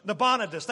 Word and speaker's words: Nabonidus: Belshazzar is actually the son of the king named Nabonidus. Boy, I might have butchered that one, but that's close. It's like a disease, Nabonidus. Nabonidus, that's better.